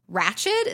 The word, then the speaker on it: Ratched: We know that Nurse Ratched, is that how you say it? – Yes.